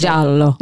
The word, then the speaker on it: giallo: And I just wanted to say, no one ever pronounces it right. – No. – Unless you're Italian. And when I didn't know any better, which was probably 2 weeks ago when we talked about this episode, probably at some point, you know, Giallo or whatever I may have said, you know, I'm going to mispronounce it a few times probably today. So I just wanted to get it out there that YouTube says that's the way the Italians pronounce giallo. – It. Giallo.